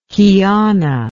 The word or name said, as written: qiana